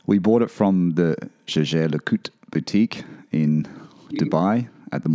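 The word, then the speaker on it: Jaeger-LeCoultre: Jaeger LeCoultre. Jaeger LeCoultre. Not Jaeger LeCoultre. That's how I used to say it. Jaeger LeCoultre.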